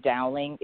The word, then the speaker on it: Dowling: If you just look up my name, Aiden Dowling, it's spelled a little different.